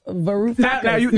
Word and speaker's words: Varoufakis: Yanis Varoufakis. – Yeah, exactly. – Varoufakis.